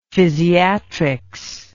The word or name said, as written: physiatrics